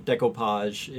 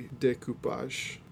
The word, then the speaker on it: découpage: So you're a hodgepodge or, you know, decoupage is... Am I pronouncing decoupage right? – Like, I just looked it French? Up. I would say decoupage.